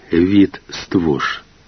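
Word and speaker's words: Wit Stwosz: Фейт Штос. Поляки произносят его имя как Вит Ствош.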